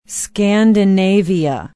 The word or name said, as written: Scandinavia